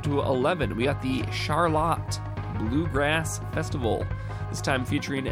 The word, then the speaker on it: Charlotte: It's spelt like Charlotte, but they say it's pronounced Charlotte.